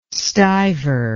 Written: stuiver

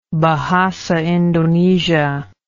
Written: Bahasa Indonesia

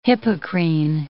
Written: Hippocrene

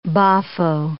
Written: boffo

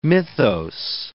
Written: mythos

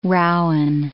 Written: rowen

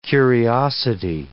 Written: curiosity

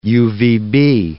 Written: UVB

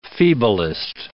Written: feeblest

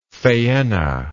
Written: phaenna

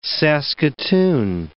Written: saskatoon

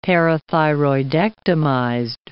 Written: parathyroidectomized

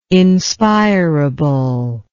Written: inspirable

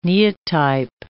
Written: neotype

